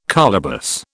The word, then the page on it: colobus: British English